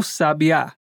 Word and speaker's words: sabiá: Pensem na escrita S-A-B-I-A. Se eu pedir para você juntar as letras, você dirá sabia. O B é a mais forte mas eu posso colocar um acento no primeiro A e transformá-la em sábia. Ou até posso acentuar a última sílaba e teremos aquele pássaro, o sabiá.